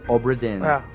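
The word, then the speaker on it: Obra Dinn: E aí disse, pronuncia como se fosse isso, cobra tin. Aí eu, tá certo, então é tipo Obradim em inglês.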